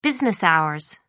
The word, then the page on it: business hours: American English